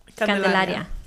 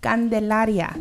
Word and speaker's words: candelaria: And how do you say it in Spanish? – Candelaria. – Candelaria.